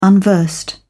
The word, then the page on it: unversed: British English